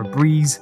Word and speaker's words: Brez: And if you do want to pick up a Brez or a Breeze, as it's actually pronounced, then check out their website and go grab yourself a can.